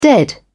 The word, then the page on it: dead: British English